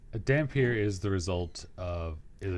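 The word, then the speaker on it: dhampir: Now, another type of vampire, and it's spelled similar to vampire, the dampier? – Mm-hmm. – Is that how you say it? – Yes.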